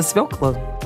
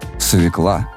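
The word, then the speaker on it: свёкла: Слово, в котором есть буква «ё», но оно не может произноситься иначе. И есть куча людей, которые говорят мне: «Нет, свекла».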